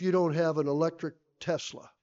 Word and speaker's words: Tesla: You know, I have people say, "Oh, you got to go get a, was it a Tesla or a Telsa?" I never can pronounce it right. What is it? – Tesla.